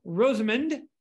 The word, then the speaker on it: Rosamond: Flying the Chinook on that in particular day, or days, this is plural, is Joe Rosamond. And he was sitting right seat in the Chinook. Is that right, Joe? Did I pronounce that right? – That is correct. Yes, both pronunciation and position. Yep.